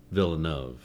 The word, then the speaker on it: Villeneuve: Denis Villeneuve. Did I say that right? – Yeah. – Villeneuve. Oh, sorry. – It depends. Close. Americans would say, yeah, Villeneuve.